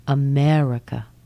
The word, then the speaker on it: America: Sometimes in one word, we can find 75% of the vowel sounds pronounced as a schwa. Let me give you an example. How about the word America? America. Let's look at that. America. America. How many schwa sounds can you hear in that word?